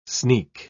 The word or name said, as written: sneak